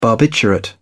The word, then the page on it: barbiturate: British English